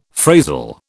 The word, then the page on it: phrasal: British English